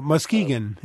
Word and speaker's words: Muskegon: I'm not sure, is that how you say it, Muskegon? – Muskegon, yeah.